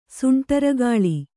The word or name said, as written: ಸುಂಟರಗಾಳಿ